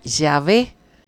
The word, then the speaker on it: Yahvé: Entonces, estos mancitos que empezaron a estudiar la Biblia, que empezaron a reconstruir cómo se pronunciaba, dijeron que la reconstrucción salía y se pronunciaría Yahvé. Sería el más acertado.